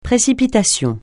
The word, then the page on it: précipitation: francais